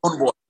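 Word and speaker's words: Stonebwoy: Stone Boy. It's just Stone Livingstone. So Stone Boy. – Stone Boy. Okay. Cause I seen a W in there, and I'm like, oh, I wonder if I'm pronouncing it right.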